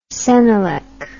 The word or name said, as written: cenelec